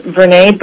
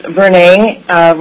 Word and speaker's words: Vernay: And for the record, my name is Vernay Berner. First name, and for the benefit of folks, is pronounced Vernay, rhymes with Renee.